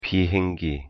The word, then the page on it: 비행기: Korean